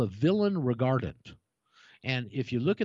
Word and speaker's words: villein: And you can go to Black's Law Dictionary, and it's got a French spelling, V-I-L-L-E-I-N, villein. But the English pronounced it villain.